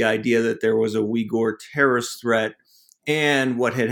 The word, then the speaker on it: Uyghur: Yeah, I'm really interested in your methodology because there's, you know, you occasionally talk about ethnographic research, having interviewed Uyghurs.